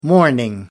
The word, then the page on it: mourning: American English